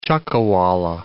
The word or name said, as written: chuckawalla